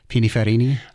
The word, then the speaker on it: Pininfarina: Pininfarina? – I've been saying that wrong for 50 years.